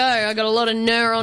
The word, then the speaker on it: gnurr: Gnr. – Gnr? – G-N-U-R-R. – Or is that just gnr? – No. – Silent G.